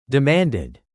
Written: demanded

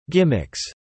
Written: gimmicks

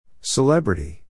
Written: celebrity

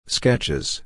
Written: sketches

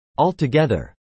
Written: altogether

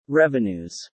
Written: revenues

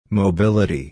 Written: mobility

